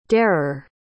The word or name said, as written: darer